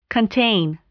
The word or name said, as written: contain